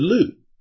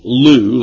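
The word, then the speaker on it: lu (לוּ): It's the Hebrew word lu. And, it's spelled in the English L-U-W. And pronounced L-O-O, Lou, like in Skip to Maloo, for lack of a better way of explaining the definition word there.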